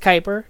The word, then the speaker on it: Kuiper: That is K-U-I-P-E-R-N-E-B-U-L-A. One more time, that's K-U-I-P-E-R-N-E-B-U-L-A. It is pronounced Kuiper. Don't worry about it. It's a reference to the Kuiper belt.